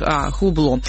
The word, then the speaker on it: Hublot: Помощник говорит ему, что Владимир Путин носит часы швейцарской марки, как это, Hublot, я не понимаю, да? Hublot, вот, в общем-то, произносит свое название как Hublot.